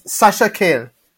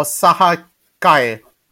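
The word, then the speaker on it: Sasha Calle: But coming back to your question on who's playing Supergirl is Sasha Kale or Saha Kale, according to Spanish pronunciation.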